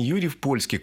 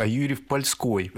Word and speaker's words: Юрьев-Польской: Кстати, правильное название не Юрьев-Польский, к Польше он не имеет никакого отношения, а Юрьев-Польской. Есть два разных вида произношения. Еще раз говорю, к Польше никакого отношения не имеет.